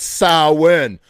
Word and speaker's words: Samhain: A lot of you are probably unaware that's properly pronounced Samhain. Yeah, okay. It's like sow, which is like female cow, and then win, like you're winning at life, okay? That's how you properly pronounce the name Samhain.